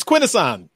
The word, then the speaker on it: Quintesson: It's quintesson.